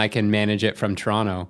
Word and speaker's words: Toronto: but you don't pronounce any of the T's. Well, except for the first one. Yeah, but it's like Toronto. – Toronto.